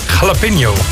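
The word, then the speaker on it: jalapeño: Bedacht in 1943 door een Mexicaanse kok die driehoekige tortillas combineerde met jalapeno, zeg ik dat goed? – Jalapeno.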